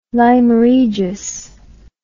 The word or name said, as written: lyme regis